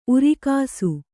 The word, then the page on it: ಊರಗಸೆ: Kannada